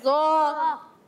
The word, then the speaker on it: ض: ضا۔ بعض بچے سے پڑھتے ہیں زوں، یہ زوں نہیں ہے، اس کا درست تلفظ ہے ضا۔